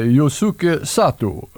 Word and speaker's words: Yosuke Sato: Han heter Yosuke Sato. – Oj, oj, oj. Fint uttal där.